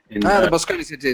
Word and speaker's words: Moscone Center: I can never pronounce it right, the Moscone or Moscone Center. – The Moscone Center in San Francisco.